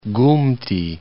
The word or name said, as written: Gumti